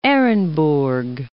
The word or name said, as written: Ehrenburg